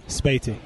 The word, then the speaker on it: Sbeity: So let me just if you may recognize the names Derek Kitchen and Moudi Sbeity. And I'm pronouncing your last name correct. – Sbeity. – Sbeity, yep.